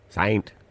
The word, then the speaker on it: Saynt: But this couple, because they want it to be registered in Australia, have had to spell it S-A-Y-N-T. – Saint. – No, it's Saint still.